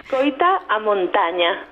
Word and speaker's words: Escoita a montaña: Vamos a escuchar otra vez, porque lo hemos escuchado, lo escuchemos ayer mismo, Escoita Monta, no sé si lo pronuncio bien. – Escoita a montaña.